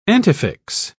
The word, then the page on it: antefix: British English